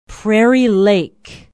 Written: prairie lake

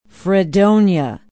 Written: fredonia

